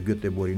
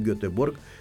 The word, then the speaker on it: Göteborg: Și eu de-aia spun și la Göteborg, spun că așa se spune în suedeză, Göteborg, nu Göteborg.